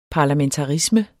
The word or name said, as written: parlamentarisme